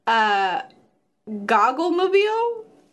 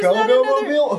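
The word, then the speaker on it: Goggomobil: I think it's go-go-mobile. Because a go-go mobile, who would want to buy one of those? – There's missing an O for go. – No, there's two O's. – Go. – Yeah, so it's spelled G-O-G-G-O mobile.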